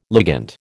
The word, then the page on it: ligand: American English